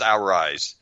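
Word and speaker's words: Arráez: I think that's how you pronounce his name, is our rise.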